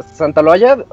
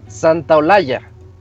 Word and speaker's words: Santa Olaya: Y luego, luego, pues mis cuates y yo volteamos y dijimos, Santa, eh, decíamos así como que es Santa, Santa Loaya, se pronuncia Santa Olaya, pero decíamos Santa Loaya.